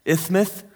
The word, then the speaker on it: isthmus: It's pronounced something along those lines. I think you're supposed to say it with a lisp, isthmus.